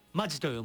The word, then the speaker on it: 本気: 根気と書いてマジと読むね。